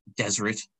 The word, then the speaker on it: deseret: We should also note that the Latin desertum has an older Egyptian origin related to the hieroglyph pronounced deseret, meaning a place that has been forsaken.